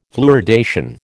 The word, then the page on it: fluoridation: American English